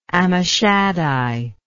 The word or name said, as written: Ammishaddai